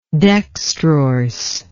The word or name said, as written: dextrorse